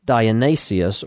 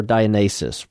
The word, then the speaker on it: Dionysus: Dionysius, or Dionysus, depending on which pronunciation of that you want to take.